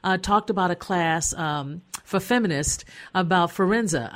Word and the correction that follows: Fiorenza: Is it Forenza? How do you pronounce her last name? Elizabeth? – Oh, Elizabeth Schussler Fiorenza.